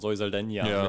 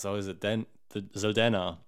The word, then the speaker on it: Zoe Saldana: Ich weiß nicht, ob es ein Podcast war oder so, aber Zoe Saldana, da war ich so, du hast nämlich auch sie immer so ausgesprochen: Zoe Saldana, mit diesem Enje im Spanischen. Und ich war so, hey, die ist Zoe Saldana.